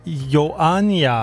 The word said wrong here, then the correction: Ioannina: Er det ikke tit ret fonetisk, det der sprog? Ioannia? – Ioannina.